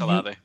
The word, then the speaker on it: Olave: And then Olave, I do not know how to say his name. You can correct me right. – if I'm okay. – No, it's Chris Olave.